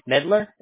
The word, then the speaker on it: Medlar: First name, J-A-Y, last name, Medlar. Am I pronouncing that correctly? – Yep. – Okay, M-E-D-L-A-R.